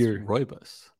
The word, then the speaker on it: rooibos: It's pronounced rooibos.